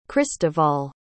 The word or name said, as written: cristoval